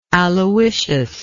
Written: Aloysius

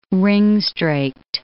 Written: ring-straked

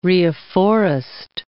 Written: reafforest

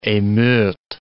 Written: emeute

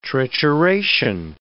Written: trituration

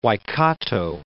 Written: Waikato

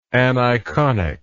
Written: aniconic

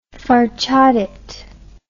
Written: farchadet

